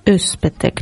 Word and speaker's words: Özpetek: E del suo libro Un giorno perfetto è stato anche tratto un film che aveva come regista Ferzan Özpetek. – Come? – Puoi ripetere piano piano, sì, perché ci perdiamo. Nome. – Che aveva come regista sì. Ferzan Özpetek, cioè Özpetek, come piace a voi.